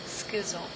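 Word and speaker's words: schizo (σχίζω): Schizo. – Is it schizo? – Yeah, like a schizo. – Schizo? – Like a schism.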